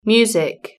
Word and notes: music: British English

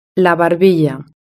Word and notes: la barbilla: Spain Spanish

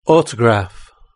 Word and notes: autograph: British English

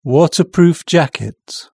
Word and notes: waterproof jacket: British English

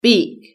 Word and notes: beak: British English

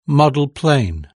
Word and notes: model plane: British English

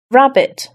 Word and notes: rabbit: British English

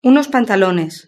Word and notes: unos pantalones: Spain Spanish

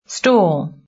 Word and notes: stall: British English

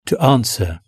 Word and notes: to answer: British English

